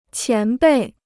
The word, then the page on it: 前辈: qián bèi